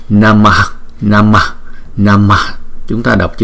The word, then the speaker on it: Namah: Cái chữ Namah này chúng ta đọc là Namah, Namah, Namah.